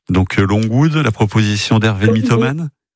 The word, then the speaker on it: Longwood: Voilà, c'était pas Longwood, c'est Longwood. – C'est Sherwood. – Pas du tout Sherwood. Longwood. Longwood. On prononce comme ça, Longwood.